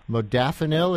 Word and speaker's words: modafinil: called modafinil.